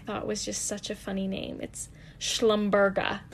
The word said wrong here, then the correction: Schlumbergera: I said it wrong. It's Schlumbergera. Schlumbergera. Not Schlumberger. I guess that's it's an oil company. Schlumbergera. That's what it is.